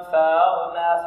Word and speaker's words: فأغنى: هذا خطأ يقع في كل من الطلاب، وهو أن الهمزة محققة فيأتي بها مساهلة. هي صحيح الهمزة النظر بها كالمتهوع وهي صعوبة في النطق، فيقول فأغنى عائلا فأغنى فأغنى. لا، فأغنى. لا صحيح، لا نلكز الهمزة، لا نلكزها، نقول فأغنى. لا، نأتي بها سهلة، فأغنى.